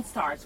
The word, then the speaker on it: Starrrz: It's Ken Starrrz with three R's.